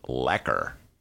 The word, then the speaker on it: Urlacher: So how would you say it rhymes with backer? So Lacker.